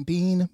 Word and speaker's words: Beane: It's A-N-T-O-I-N-E, last name Bean, B-E-A-N-E. It's green bean, but with an E on the end.